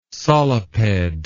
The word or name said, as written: soliped